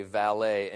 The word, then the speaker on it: valet: Now, we use the French pronunciation for the word. We say valet, and we've ruined the noun.